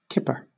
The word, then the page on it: kipper: British English